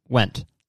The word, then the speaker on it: Wendt: Is that how you say it? Wendt. Ian Wendt to AlphaCon to do a speech. Okay. Ian, W-E-N-D-T. Wendt.